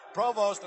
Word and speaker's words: Provost: And for those of you who may be wondering about the pronunciation of Ted Provost's name, it is a French name, initially from Canada. Provost, an American boy who went to Ohio State, prefers to be called that way.